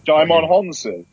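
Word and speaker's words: Djimon Hounsou: Beyond that, I watched the film Air starring Norman Reedus and Dion Hosanna, is it? I can never remember how to pronounce that. – Daimon Honsu.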